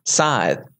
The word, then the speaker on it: Scythe: Or Scythe? Are we saying this wrong? Scythe? Scythe. – What are you saying? – Scythe. – That's how you say it. – Is that how you say it? – You say it as if it was spelled S-I-T-H-E.